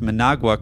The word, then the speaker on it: Managua: He mistakenly said I was in Managua, and you pronounce Managua correctly.